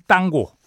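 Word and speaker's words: tango: 네, 리베르 탱고 들었습니다. 이게 뭐 정확하게 얘기하면 땅고 이 친구들이 땅고라고 발음하더라고요.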